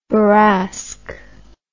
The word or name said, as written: bourrasque